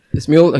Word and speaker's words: Smule: no sé pronunciarla, pero es Smule. – Smule, exacto, okay.